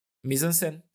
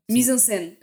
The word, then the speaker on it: mise en scène: Uh, uh, mise en scène. – Mise en scène.